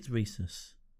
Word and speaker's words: rhesus: So I think it's rhesus. I'm not sure though. – I'm okay with that. – Tell you, yeah. If you're okay with it, I'll tell you that the rhesus antigen, and it's spelled R-H-E-S-U-S. – Okay, so not like the peanut butter.